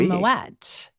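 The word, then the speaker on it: Moët: And during that call, please tell me you looked up the proper pronunciation of Moet. – Yeah, I knew. Moet? I knew that it was Moet.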